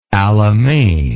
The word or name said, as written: Alamein